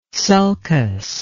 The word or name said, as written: sulcus